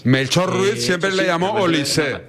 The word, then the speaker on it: Olise: Oye, el otro día tuve una agria polémica con los compañeros porque tú me garantizaste que se pronuncia Olis y me pusieron cortes del propio jugador que dijo que es Olise. – No, efectivamente se dice Olise,